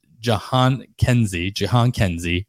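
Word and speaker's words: Jhonkensy: and that is Jahans. I actually put the pronunciation here so I wouldn't mess it up, but I still mess it up. It's Jahan Kenzie, Noel, first baseman prospect for the Cleveland Guardians.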